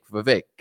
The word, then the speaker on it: Vivek: It rhymes with snake. It rhymes with cake. Vivek.